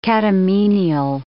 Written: catamenial

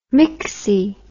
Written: myxy

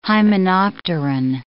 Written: hymenopteran

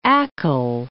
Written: Achill

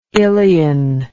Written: ilion

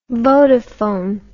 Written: vodafone

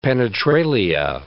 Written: penetralia

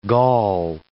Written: gall

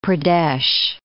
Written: Madhya Pradesh

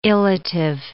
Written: illative

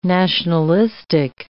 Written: nationalistic

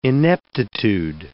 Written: ineptitude

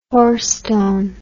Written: hoarstone

